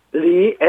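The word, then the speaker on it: 리 (梨): 먹는 배는 리, L-I 해가지고 이성입니다. 리. – 리. – 이것은 떠나자 헤어지다, 이별하자 할 때 이자하고 발음이 완전히 같습니다. 이것도 똑같이 이성이거든요. 리.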